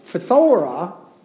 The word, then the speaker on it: phthora: It's phthora. No, we don't usually pronounce words like that, but that's the way it's pronounced.